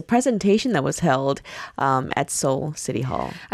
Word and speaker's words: Seoul: Yes, in English, if I pronounce it like that, it rhymes. But it sounds the same. It's Seoul, my soul.